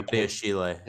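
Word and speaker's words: Benoît Badiashile: Benoit Badiashile.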